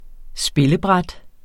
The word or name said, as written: spillebræt